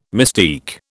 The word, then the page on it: mystique: British English